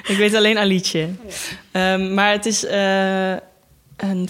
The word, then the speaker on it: Alice: De nieuwe film van een regisseur wiens naam ik pas recentelijk heb leren uitspreken, namelijk Alice Rohrwacher en niet Alice. Want het is Italiaans.